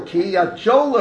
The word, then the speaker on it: Kiryat Joel: She should have said Joel, Kiryat Joel already, Joel.